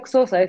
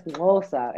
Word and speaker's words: Xhosa: Pero no se pronuncia Xhosa, es Xhosa, es como un clic que uno hace con la lengua.